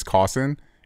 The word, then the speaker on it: Cossin: And that's my wife's biggest gripe about being married with me, really, is my last name, because legally her last name is Cossin. And no one can pronounce it over the phone. Everyone thinks when you say S, they say F. Like, oh, coffin. Got it. We're like, no.